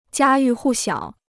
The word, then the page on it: 家喻户晓: jiā yù hù xiǎo